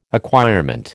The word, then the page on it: acquirement: American English